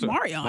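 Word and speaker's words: Mar'Reion: And see, now this family has set me up where I just, they need pronunciation keys because it's M-A-R apostrophe, capital R-E-I-O-N. – Oh, Marion. – Man. – Marion, right? – That's Marion.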